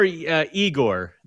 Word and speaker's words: Yegor: It's more Igor than Yegor.